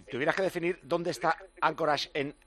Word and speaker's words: Anchorage: Anchorage, me parece que se dice. ¡Hola! – Sí, hola, ¿qué tal? – ¿Cómo estás? – Muy bien, y se pronuncia Anchorage.